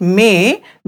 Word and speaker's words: में: Both of them are pronounced differently. Just pay attention to their pronunciation. While pronouncing main, I'll repeat main, that means I, mouth is widely opened. While pronouncing may, I'll repeat may, that means in, mouth is not opened so much.